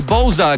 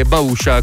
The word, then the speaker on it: Bousa: Si intitola Magnetic, guadagna 5 posizioni il progetto di The Bousa, che si scrive Bousa e si può pronunciare anche Bausha, come ovviamente la pronunciano gli amici che arrivano dalla Lombardia.